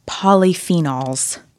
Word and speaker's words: polyphenols: Polyphenols. – Wow, that was like amazing. I feel like that was like exactly how it should be pronounced.